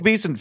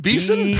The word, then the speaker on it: Besson: It was Luke Besson film. It was, what name are you saying? – Luke Besson? – Besson.